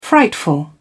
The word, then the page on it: frightful: British English